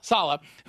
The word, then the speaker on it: Saleh: Saleh, who's the head coach at the Jets.